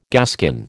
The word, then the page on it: gaskin: American English